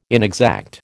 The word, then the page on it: inexact: American English